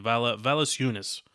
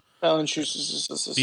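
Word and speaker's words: Valančiūnas: I mean, their center went for, I can't pronounce his name, Valasiunas. – Valanciunas.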